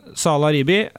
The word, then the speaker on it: Salah Ribi: Ja, for at pappaen din, Salah Ribi, nå må du igjen ta uttalen min, den er riktig østerørsk, sant? – Ja, det er bra det.